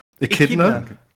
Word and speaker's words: echidna: Is it a Echidna? – Echidna. It's an echidna. It's echidna. Yeah, echidna. echidna.